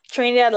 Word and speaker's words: Trinidad: Trinidad, like the country? – Yes.